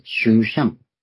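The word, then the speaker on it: シ ュー シャン (שושן): これが、シューシャン。シューシャンっていうヘブライ語ままの発音では、ピンとこないかと。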